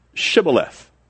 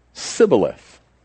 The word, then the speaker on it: Shibboleth: If he said no, then they would say to him, then say Shibboleth. And he would say Sibboleth, for he could not pronounce it right.